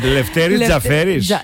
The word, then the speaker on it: Λευτέρη Τζαφέρη: Ναι, το τονίζω σωστά, γιατί έχει και τόνο. – Ομοίο καταληκτικό είναι το όνομά του. Λευτέρη Τζαφέρη.